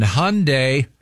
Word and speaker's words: Hyundai: Right now, I want to talk to you about things that rhyme, like Sunday and Hyundai.